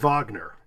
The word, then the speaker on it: Wagner: The thing that gets me, though, is there's a college in New York that's named after Wagner, and it's spelled W-A-G-N-E-R, but it's pronounced Wagner. – Oh, really? – So then you really get, it's Wagner College. It's not Wagner College.